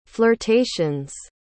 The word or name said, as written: flirtations